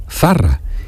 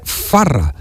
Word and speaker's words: Zarra: Entonces Zarra es con Z, Zarra. Y él gritó al mundo entero el segol de Zarra y decía Farra, porque cambiaba la Z por la F.